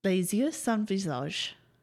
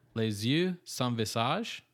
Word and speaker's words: Les Yeux Sans Visage: Les Yeux Sans Visage. – Les Yeux Sans Visage? – Yeah.